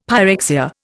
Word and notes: pyrexia: Indian

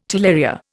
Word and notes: talaria: Indian

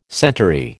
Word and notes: centaury: American English

